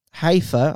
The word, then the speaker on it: Haifa: So a woman from the Israeli city of Haifa, I don't know if I pronounced that correctly. – That's right.